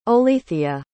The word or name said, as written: Olethea